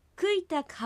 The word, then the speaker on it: クイタク: クイタクハウェエーラムアン。 – クイタクは実際にはうとイが一塊に発音されてクイタクのように発音されることに注意してください。